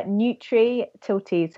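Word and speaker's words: Nutritilty: but it's uh, Nutri Tilty, so it's N U T R I T I L T Y. So Nutri as in nutrition and Tilty as in neutrality.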